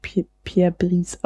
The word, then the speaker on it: Pierre Brice: Pierre Brice wird natürlich Pierre Brice ausgesprochen.